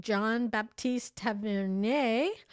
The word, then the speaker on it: Jean Baptiste Tavernier: So it goes back that the original blue diamond had been roughly cut from 112 carats when French diamond merchant Jean Baptiste Tavernier, I said that right.